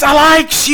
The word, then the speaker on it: Alyx: I don't know how they're pronouncing that. – Alex? – Alex? I know somebody's sitting there yelling at me. – It's Alex, you stupid idiot!